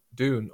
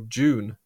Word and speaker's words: Dune: where we'll be talking about Dune, or June as it's pronounced in the UK.